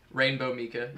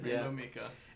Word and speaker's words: Rainbow Mika: Armika, I think that's how you pronounce Rainbow. – It. Rainbow Mika. – Rainbow Mika.